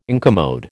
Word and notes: incommode: American English

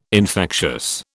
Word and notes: infectious: British English

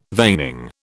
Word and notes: veining: British English

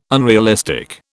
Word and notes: unrealistic: British English

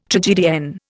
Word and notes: tragedienne: Indian

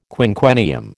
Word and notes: quinquennium: American English

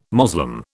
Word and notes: Moslem: British English

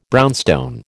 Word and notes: brownstone: American English

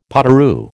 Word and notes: potoroo: American English

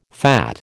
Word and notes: fat: American English

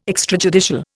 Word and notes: extrajudicial: Indian

